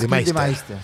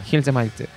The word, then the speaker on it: Gildemeister: Hans Gildemeister. Gildemeister.